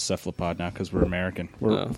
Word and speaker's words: cephalopod: So in Greek, the C is pronounced, it's always a hard C. There's no soft C sound in Greek. – Yeah. – So, you know, Caesar is not Caesar, it's Kaiser. So technically, cephalopod is correct, except for, like, everybody says cephalopod, so.